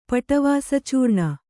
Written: ಪಟವಾಸಚೂರ್ಣ

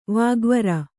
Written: ವಾಗ್ವೀರ

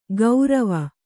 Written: ಗೌರವ

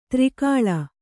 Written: ಧಾರೆಗಾಳೆ

